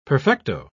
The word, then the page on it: perfecto: British English